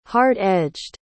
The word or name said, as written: hard-edged